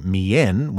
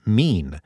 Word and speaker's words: mien: I've been saying mien when it's pronounced mean.